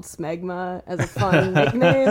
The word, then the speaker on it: smegma: Oh my god, there's a subreddit for shmegma. – Shmegma? How do you spell that? – S-M-E-G-M-A. – There's no S-C-H? Only two posts. – I've been saying shmeg. – No, smegma. Just smegma.